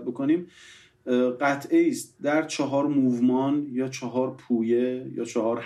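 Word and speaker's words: mouvement: یعنی اساسا اسم موومان یا موومنت به انگلیسی، موومان در واقع تلفظ فرانسوی شد، برمیگرده به تمپو.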